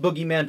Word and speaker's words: Bogeyman: Speaking of speaking of the Bogeyman, which is it's pronounced Boogeyman, but spelled Bogeyman for whatever reason.